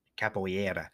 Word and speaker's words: Capoeira: Capoeira. Capoeira. That's how they pronounce it.